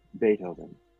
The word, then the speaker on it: Beethoven: He mentioned Beethoven. She had read in the library about that musician. His name was pronounced with an A and spelled with a double E.